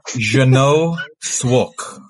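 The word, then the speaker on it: Jeannot Szwarc: Well, we're going for Santa Claus the Movie from 1985, directed by Jeanette Svag. – I've written it. Hold on. I've written it. How do you pronounce it? – It's Jeno Swark.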